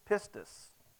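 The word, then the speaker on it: pistis (πίστις): It is pistis, okay? That is the appropriate term. My wife wanted me to make sure, because I'm not good at English, all right? But she said, you need to make sure that that's the way you pronounce that.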